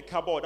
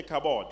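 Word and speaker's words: kabod (כבוד): I want you to take your hand and touch your head and say kabod. – Touchez la tête et dites kabod. – Now it's K A. It may pronounce ka, but it's ka. It's Hebrew. Kabod.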